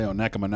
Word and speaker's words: Onekama: You just want to say Onekama now that you know how. – I do know. Because it's spelled like one comma. That's how it's spelled.